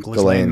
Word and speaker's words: Ghislaine: Ghislaine Maxwell.